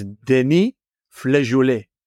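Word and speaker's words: Denis Flageollet: And we also put in a request for, as David taught us, to pronounce the name correctly, the founder of De Batoon, which is Denis Flejoulet.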